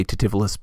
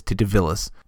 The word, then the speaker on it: Titivillus: Now, I will always say Titivillus, but by the rules of Latin pronunciation, it is Titivillus.